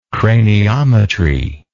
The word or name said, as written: craniometrist